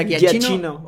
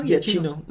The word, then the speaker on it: Giacchino: ¿Cómo se pronuncia? – Giacchino. – O Guías. Sí, ¿no? – Giacchino.